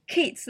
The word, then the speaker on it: Keats: In the story of the poet, I found out that it's actually like Keats, like John Keats, like the poet.